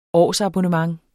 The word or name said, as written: årsabonnement